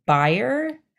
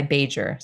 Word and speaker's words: Bajer: You know, I think it's a Polish name. No, I know it is because it's my name, but it's, you pronounce it Bajer, but I've been going by Bajer. So I like to say Major Bajer.